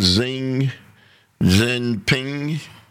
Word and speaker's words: Xi Jinping: And with Xi Jinping, is that how it's pronounced? – Yes.